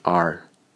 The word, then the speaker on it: hour: 这些词的弱读形式在托福听力中都特别常见，比如 hour 这个单词，大多数情况下都被读成 r。